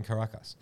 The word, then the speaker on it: Caracas: Introduce Manny, a young Venezuelan musician passionate about Afro-Venezuelan music in a poor neighborhood in Caracas. Did I say that right? – Yeah, pretty good. – In Caracas.